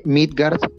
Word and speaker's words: Meadgard: Ese Instagram es eh, Midgard, se escribe M-E-A-D-G-A-R-D.